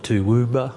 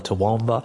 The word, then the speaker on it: Toowoomba: People call it Toowoomba or Toowoomba.